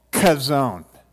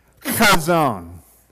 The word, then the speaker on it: Chazown: and i'm going to kind of show you how to pronounce it it's called Chazown all right you got to act like you're about ready to hawk a loogie all right Chazown can you say it one two three on the count of three one two three Chazown.